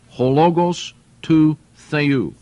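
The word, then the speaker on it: Ho logos tou theou: Hologos tou theou. This is the way it would be pronounced in the Greek.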